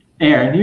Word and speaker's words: Aaron: Aaron, you're good, Brandon, okay.